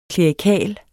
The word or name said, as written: klerikal